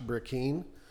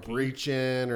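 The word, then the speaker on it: Brecheen: Most people mispronounce his last name, too. You know, they call him Breechin or anything. I tell everybody, you can call him whatever you want. Just don't call him Britches.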